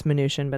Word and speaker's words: Mnuchin: Treasury Secretary Steve Mnuchin is I know it's Mnuchin, but I just mispronounce it because he hates it.